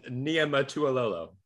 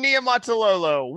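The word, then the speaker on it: Niumatalolo: Ken Niumatuololo. – Just give that one another shot, my friend. – Ken Niumatuololo. – Nope, it's Ken Niumatuololo.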